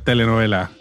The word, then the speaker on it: telenovela: sounds a lot like a telenovela. Did I say that correctly? Is that the right word? – Yes, a Mexican telenovela.